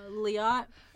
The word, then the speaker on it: Liat: Liat? Uh-huh. It rhymes with Fiat.